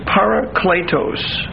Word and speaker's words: parakletos: The word advocate in Greek is parakletos. P-A-R-A-K-L-E-T-O-S. Parakletos. It's a long E.